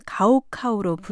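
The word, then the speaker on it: 가오카오 (高考): 한자로 높을 고, 생각할 고를 쓰는 중국 발음 가오카오로 부르는 시험입니다.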